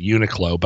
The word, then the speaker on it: Uniqlo: You could buy a polo shirt I pronounce it Uniqlo, but if you want to say Uniqlo,